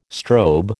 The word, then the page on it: strobe: American English